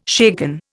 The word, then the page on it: shaken: Indian